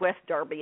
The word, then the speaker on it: West Derby: So when John, Paul, George, and Pete, and of course Neil Aspinall, leave from the Jolly Miller way out in West Derby, it's spelled West Derby, it's pronounced West Derby,